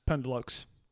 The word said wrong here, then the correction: pendeloque: Actually, no, I mispronounced it. Pandeloke.